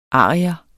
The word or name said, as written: ARIERNE